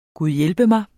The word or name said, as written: gud